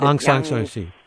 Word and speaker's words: Aung San Suu Kyi: Aung San Suu Kyi.